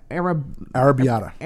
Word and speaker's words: Arrabbiata: Arrabbiata. – Arrabbiata pizza.